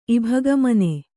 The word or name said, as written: ಇಭಗಮನೆ